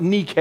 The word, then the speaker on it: Nike: Now, the word that we see here translated victory in the second part of verse number 4 is, in the English language, it's based off of the Greek word Nike, or properly pronounced Nike. I don't know if you knew that. That's what it's supposed to be.